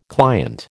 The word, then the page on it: client: American English